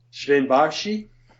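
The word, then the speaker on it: Bärtschi: How do you pronounce it? – Sven Barshi.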